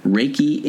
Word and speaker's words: Reiki: Reiki energy healing. Did I say it right? – Yes.